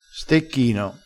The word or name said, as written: stecchino